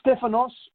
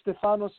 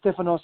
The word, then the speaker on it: Stefanos: Yeah, Stefanos or Stefanos is fine. Yeah, it's all Stefanos. – Good, man. I'm not one to get. Yeah, Stefanos is the original pronunciation, but I'm not going to get easily offended.